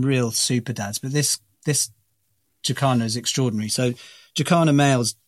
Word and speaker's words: jacana: And I was asked, or I was, it was suggested that I ask about the jacana. Is that how you say it? Or jacana? – Jacanas, yeah. Jacanas, they're also called lily trotters, and it's pretty extraordinary, that story, actually.